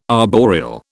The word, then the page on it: arboreal: British English